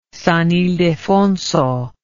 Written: San Ildefonso

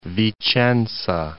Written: Vicenza

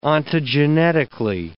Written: ontogenetically

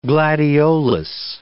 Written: gladiolus

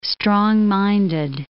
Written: strong-minded

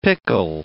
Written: pickle

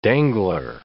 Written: dangler

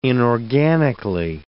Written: inorganically

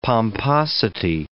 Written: pomposity

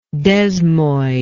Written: desmoid

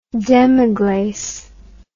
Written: demiglace

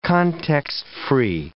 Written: context-free

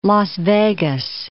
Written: Las Vegas

